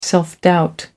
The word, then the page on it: self doubt: American English